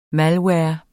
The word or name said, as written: malware